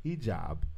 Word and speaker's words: hijab: hijab.